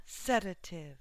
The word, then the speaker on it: sedative: Sedative, first syllable stress.